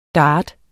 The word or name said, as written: dart